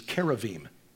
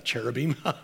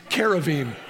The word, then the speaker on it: Keruvim: And the Keruvim, our Jewish friends tell us the actual pronunciation is Keruvim. So you can impress your friends. Tell us, say, no, it's not Cherubim, you Philistine. It's Keruvim.